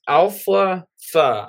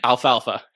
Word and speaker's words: Alfalfa: Is Alpha Fa. I'm really hoping I pronounce that right. – Alfalfa.